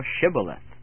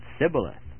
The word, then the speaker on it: Shibboleth: In the book of Judges we read, "Say now Shibboleth," and he said Sibboleth, for he could not frame to pronounce it right.